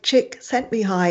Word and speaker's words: Csikszentmihalyi: His name is like Chick sent me high. It's the thing that sends you high that is the peak experience. So it's very easy to remember the pronunciation.